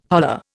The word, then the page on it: holler: Indian